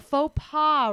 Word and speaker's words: faux pas: Like you did a faux pas? – A faux pas, right. Mm-hmm. But it's spelled with an X at the end.